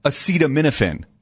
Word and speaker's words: acetaminophen: I've been taking a lot of acetaminophen.